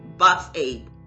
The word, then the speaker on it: Boxabl: Let me make sure I'm saying it right. Yeah. Boxabl. B-O-X-A-B-L.